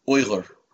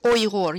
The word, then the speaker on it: Uyghur: Even the word Uyghur, I feel like I mispronounced it. The way you said it was Uyghur, right? – Uyghur, yes. Uyghur.